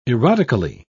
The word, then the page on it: erotically: British English